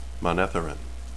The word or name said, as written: manetheren